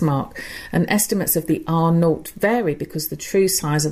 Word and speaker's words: R0: Every infectious disease is given a reproduction number, which is known as RO, pronounced R0, actually.